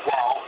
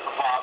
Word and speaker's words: Waw: And the four letters of the name are Yod, He, Waw, He. Some may pronounce it Yod, He, Vav, He. However, Yod, He, Waw, He is the most ancient form of the pronunciation of these letters.